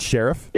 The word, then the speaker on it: Scherff: Well, you see my brilliance, Marcus, is talking about how no one's really heard of him and then mispronouncing his name, right? So it's what I call him, Scherff. It's Scherff.